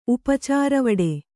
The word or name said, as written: ಉಪಚಾರವಡೆ